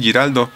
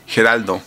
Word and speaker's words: Giraldo: Luego por acá dice, posteriormente se casó con el guitarrista de la banda Neil Giraldo, incorrectamente pronunciando como Geraldo en los créditos, con quien tiene dos hijas, Haley y Hannah Giraldo.